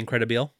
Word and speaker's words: Incredibile: Is that how you say it? The Incredibile? – I would call it the Incredibile.